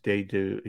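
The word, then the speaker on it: Dedeaux: You know how to pronounce his name, Jody? It's Dedu. He used to be a pitcher with the Dodgers. It's D-E-D. E-A-U-X. – Dado.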